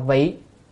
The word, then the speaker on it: vị: Thường thường vì chữ vị này có hai âm, chữ này có hai âm, một âm là vị, một âm là vi. Nếu vi á, là hay là làm, ở đây chúng ta phải đọc là vị, có nghĩa là vì, luôn luôn vì, hay là thường thường vì.